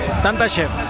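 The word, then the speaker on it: Tantashev: Tanta chef, ¿está bien pronunciado? – Sí, sí, tanta chef. Tranquilo, tanta chef.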